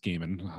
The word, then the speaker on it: Gaiman: Uh, Lorraine Garland is actually was Neil Gaiman's or Gaiman's, I suppose it's properly pronounced Gaiman, um, at least according to the Sandman Audible uh, soundtrack that I've been listening to.